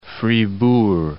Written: Fribourg

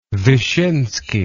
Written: vishinsky

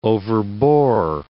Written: overbore

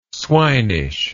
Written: swinish

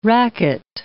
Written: racket

